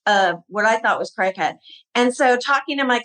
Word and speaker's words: Cricut: And cricket is spelled C-R-I-C-U-T. And for years, I was pronouncing this cricut.